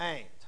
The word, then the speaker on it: ant: A-N-T. It's pronounced the same as your mother's sister. It's spelled different. That's spelled A-U-N-T, but it's pronounced ain't. And this is spelled A-N-T, and it's pronounced ain't.